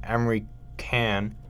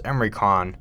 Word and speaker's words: Emre Can: And Emre Can, but I'm pretty sure it's pronounced Emre Can.